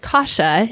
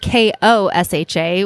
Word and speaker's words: Kosha: So we're going to name, they started just rhyming and came up with Kosha, and it's spelled K O S H A, where everybody would say, no, it's supposed to be K A. um, you know what? I will answer to anything close. – Most common mispronunciation is it Kosha? – Kosha. – Okay. Kosha. Wow. Yep.